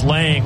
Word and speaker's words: Lange: And now it is Lang, which is spelled L-A-N-G-E.